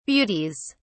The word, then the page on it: beauties: British English